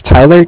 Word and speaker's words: Tyler: My name is Tyler, T-Y-L-E-R, and I'm calling with Cardiff. It's spelled C-A-R-D-I-F-F.